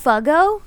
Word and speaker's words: Fugo: It is spelled F-U-G-O. – But is it Fugo. Perhaps Fugo? – No, it's Fugo.